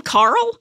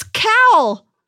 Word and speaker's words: Khal: Is his name Carl? – No, it's Cal. – Oh, Cal.